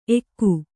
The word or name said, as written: ಎಕ್ಕು